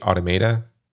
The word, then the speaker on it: automata: He experimented with automata. Is that how you say it? Automata? – Yes, like automata. – Automata, okay.